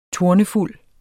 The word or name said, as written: tornefuld